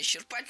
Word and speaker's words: исчерпать: Но никогда нельзя исчерпать. Мы же просто приводим какие-то наиболее характерные примеры. – Исчерпать, правильно. Это слово прошло долгий путь и поменяло ударение.